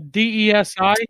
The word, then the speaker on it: Desi: DESI? – I think. Desi, though. Like that's how you say it.